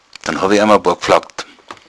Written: gflaggd

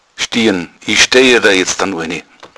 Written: stiern

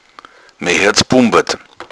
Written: bumbern

